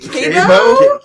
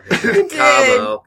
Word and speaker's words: Cabo: Like, Cabo? I said it wrong. Cabo.